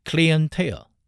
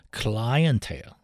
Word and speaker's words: clientele: 它这个英文呢，我们叫做 client，c l i e n t，它的复数呢，c l i e n t e l e，啊，那英文我们叫做 clientele，美国人发音不太一样，美语的发音是 clientele，啊，其实写法都一样，英文是 clientele，美语是 clientele，都是代表 client 的复数。